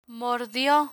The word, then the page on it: mordio: Spanish